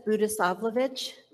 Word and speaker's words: Budisavlovich: My name is Lisa Budisavlovich.